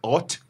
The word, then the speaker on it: haute: Is it haute? You have to be French enough to say. – No, you don't. You said it beautifully. Haute couture. – Oh, okay. – So that's the inspiration. So it's not H-O-T because that's just plain and.